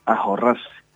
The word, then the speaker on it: ahorrarse: Se pronuncia generalmente ahorrarse.